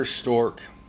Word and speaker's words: Strzok: It's Peter Strozark and Lisa Page all over again. I totally butchered that guy's last name. Peter Stork, S-T-R-Z-O-K, Stork.